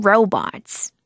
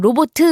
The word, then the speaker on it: robot: 그리고 로봇, 근데 너의 로봇들이라고 하면 your robots. Your robots. 그렇죠? 친구들 발음 로봇 이렇게 많이 하는데 로봇 하지 마시고요. Robot. Robot.